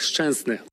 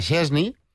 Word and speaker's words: Szczęsny: Estàvem acostumats a Szczęsny, però és que no està ben pronunciat. Llavors és Szczęsny, que segurament no ho faig del tot bé, però s'apropa més a com ho pronuncien els polonesos com Robert Lewandowski.